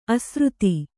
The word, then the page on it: ಅಸಾರತೆ: asārate